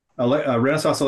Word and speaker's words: Renesas: Is it pronounced Renesas? – Yes. – Mm-hmm. Renesas Electronics America.